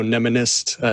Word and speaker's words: mnemonist: Yeah, mnemonics, so nemonist is how I pronounce it. I don't think it's made it into the dictionary quite yet, but basically person who studies and creates mnemonics and teaches even.